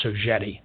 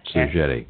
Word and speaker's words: Sogeti: And by the way, the pronouncing the company is also something that a lot of people have challenges with, but it's Sogeti is actually an acronym, a French acronym for IT services company. So it's an acronym, and it's pronounced Sogeti.